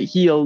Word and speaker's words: Gil: I don't know how to say his last name because it just looks like either. – Gil. But all the Yankees announcers have been calling it Heel, Luis Heel, Louis Heel, stuff like that.